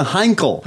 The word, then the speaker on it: Heinkel: Bagel from the Misfits is building a Heineken, or how do you say it? A Heinekel? – It's a Heinkel.